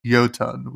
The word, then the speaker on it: Jotun: It's pronounced Jotun with a J.